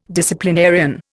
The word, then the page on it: disciplinarian: Indian